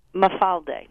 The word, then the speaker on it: mafalda: Mafalda.